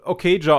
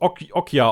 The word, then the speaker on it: Okja: Also erstmal, das fing schon damit an, wie du Okja ausgesprochen hast. Das ist Okja ausgesprochen. Das war, da hat es mich schon das erste Mal zerrissen. – Okja? – Also in der deutschen Version sagen die Okja.